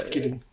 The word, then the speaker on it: Gidding: Und der Drehbuchautor Nelson Gidding oder Jidding, wie wird er ausgesprochen? Der Gidding.